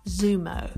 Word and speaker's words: Xumo: So the first one is called Xumo. And it's spelt X-U-M-O.